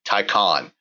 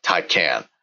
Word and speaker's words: Taycan: This is basically the Porsche Taycan, and I got confirmation from somebody at Porsche the correct pronunciation will be Taycan, as in as if it was spelled T-A-Y-C-O-N, not Taycan. So this is built on the same Taycan platform.